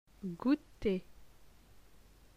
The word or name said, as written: Gouter